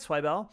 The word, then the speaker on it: Zweibel: Am I saying that right, Zweibel? – Yes, you are.